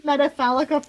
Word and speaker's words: Metafalica: It's called Metafalica for a reason, in that it looks phallic. Metafalica looks like a giant penis.